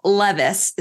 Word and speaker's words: Levis: The two main stories Will Levis, is that how you say it? – Yes, it is.